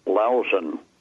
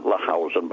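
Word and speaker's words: Lahousen: Number 10, that's General, pronounced Lausen, not Lahausen, but Lausen.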